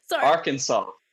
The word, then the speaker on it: Arkansas: Arkansas.